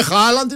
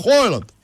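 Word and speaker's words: Haaland: Και ο Χάλαντ που ξέραμε δεν είναι Χάλαντ, είναι Χόλαντ.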